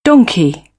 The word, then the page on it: donkey: British English; ɒ